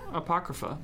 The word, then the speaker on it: Apocrypha: Okay, the next one is popular apocryphi? Apocrypha? – Apocrypha. – Apocrypha. – A capocrypha. – You butchered it.